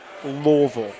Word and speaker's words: Louisville: So instead of Louisville, you just say it like it was L-L-V-L-L. Louisville. Yeah. – Louisville.